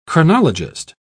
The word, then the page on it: chronologist: British English